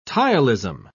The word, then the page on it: ptyalism: British English